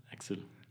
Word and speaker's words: Axel: Is this how you pronounce it in Austria? Axel? In the US we would say Axel, like Axel Rose. – Axel.